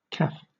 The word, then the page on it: caff: British English